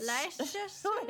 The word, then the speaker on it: Leicestershire: Which, Leicestershire. – Which rhymes with Worcestershire sauce.